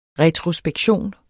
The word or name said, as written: retrospektion